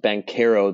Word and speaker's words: Banchero: Liddell is probably going to guard Paolo Banchero, which actually he said he wants his name pronounced Banchero, the Italian pronunciation, but I don't think that's going to catch on.